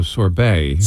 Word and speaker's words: sorbet: No, sorbet. – Sorbet. – Yeah. – Which is the same as sherbet. – Sorbet. – Yeah. I know how. – Sorbet. – The T is silent.